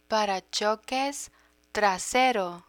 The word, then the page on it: paracoques trasero: Spanish